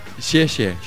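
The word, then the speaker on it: xie xie (谢谢): Pero te dicen, decí gracias y te dicen, ¿cómo se dice? Se dice xie xie. – Xie xie.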